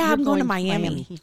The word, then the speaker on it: Miami: There is a town called Miami, and it's spelled like Miami, Florida, but it is. – You say Miami.